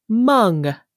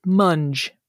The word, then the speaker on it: mung: Your word today is Mung, M-U-N-G, also sometimes pronounced Munge, M-U-N-G. – Oh, Munge. – Yeah, I would say more historically pronounced Mung.